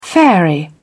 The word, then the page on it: fairy: British English